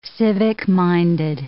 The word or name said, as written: civic-minded